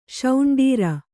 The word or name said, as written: ಶೌಂಡೀರ